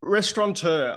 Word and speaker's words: restaurateur: Well, how do you even pronounce that word, right? Restauria? – Restaurateur. I was 20-something years, but not anymore.